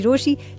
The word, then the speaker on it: Rochie: मेरा नाम है मिर्ची रोशी, नाम की स्पेलिंग है ROCHIE।